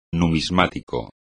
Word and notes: numismático: espagnol

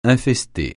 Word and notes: infester: francais

